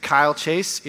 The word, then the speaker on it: Kyle Chase: If you don't know me, my name is Kyle Chase. It rhymes with smile face if you need help remembering.